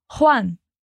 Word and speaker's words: Juan: Vamos nos atentar à pronúncia do Juan, que falamos com aquele som de R da garganta, como a gente já viu em outros episódios, como na palavra raro. Repete comigo. Juan.